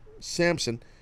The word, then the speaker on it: Samson: So they did a good job, WWE, showing the B-roll footage of the previous week where Samson, am I saying his name wrong? Is it Samson or Samp with a P, some? – No P. – Samson drills, you know, Finn in the head last week with the guitar.